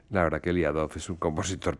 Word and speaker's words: Liadov: Era Liadov. – Liadov,